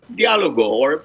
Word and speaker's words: Dialogo: And ultimately, he publishes, is it pronounced Dialogo? – Dialogo, but it's a dialogue.